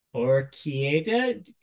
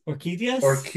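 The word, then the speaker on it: Orchídeas: I'm going to try to pronounce the album name here. Orchideus? – Orchideas.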